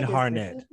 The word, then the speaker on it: Sinead Harnett: Sinead Harnett.